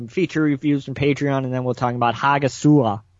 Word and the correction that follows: Hagazussa: Is that how you pronounce it, Derek? – Hagazussa. Oh, it's close H enough. – Hagazussa. The H is silent. Well, that's a yeah. good. That's a good uh, explanation of how to say it.